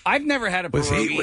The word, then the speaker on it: pierogi: Pierogi. – And that is the proper pronunciation.